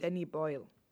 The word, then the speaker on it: Boyle: Der ist von Danny Boy, äh, nee, Boyle. Boy, Boyle, wie spricht man den aus? Danny Boyle. Danny Boyle, okay, Entschuldigung.